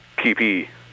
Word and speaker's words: kepi: How do you pronounce that? Is it Kepi or Keepy? K-E-P-I. – Keepy.